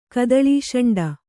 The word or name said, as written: ಕದಳೀಷಂಡ